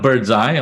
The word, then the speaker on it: Birdseye: So, and we call it Birdseye, although it's spelled B-I-R-D-Z or Z-I, and Birdseye giving you a bird's eye view of the store.